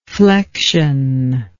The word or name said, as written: flection